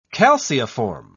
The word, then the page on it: calceiform: British English